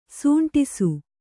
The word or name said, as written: ಸಂಡಾಸ